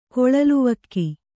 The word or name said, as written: ಕೊಳಲುವಕ್ಕಿ